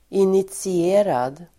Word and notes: initierad: [initsi'e:rad]

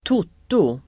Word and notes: toto: [²t'ot:o]

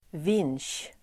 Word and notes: vinsch: [vin:sj]

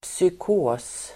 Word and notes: psykos: [psyk'å:s]

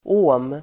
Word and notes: ohm: å:m

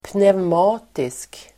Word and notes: pneumatisk: [pnevm'a:tisk]

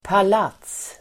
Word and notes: palats: pal'at:s

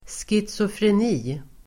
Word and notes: schizofreni: [skitsofren'i:]

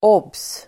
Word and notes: obs: åb:s (el. åbserv'e:ra)

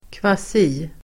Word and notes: kvasi-: [²kv'a:si-]

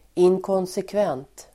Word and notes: inkonsekvent: [inkånsekv'en:t]

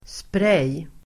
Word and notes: spray: [sprej:]

